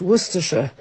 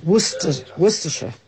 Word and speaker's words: Worcestershire: It's pronounced Worcestershire. The same way as you say Yorkshire and Lancashire and Leicestershire. So it's Worcestershire. – So now you know? – Worcestershire sauce.